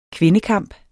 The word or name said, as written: kvindekamp